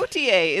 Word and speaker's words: Gautier: As Luke has said in the multiple times they've talked about it, he always calls it Gautier. And I'm like, no, got milk? – It's Gautier.